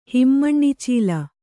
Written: ಹಿಮ್ಮಣ್ಣಿಚೀಲ

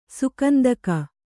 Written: ಸುಗಂಧಿಕ

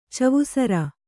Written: ಚವುಸರ

